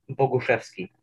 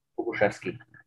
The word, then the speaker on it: Boguszewski: Boguszewski. – Boguszewski. – Boguszewski.